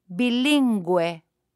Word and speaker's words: bilingüe: If there are two little dots on top of the U, then the U is pronounced. Listen and repeat. – Pingüino. Bilingüe.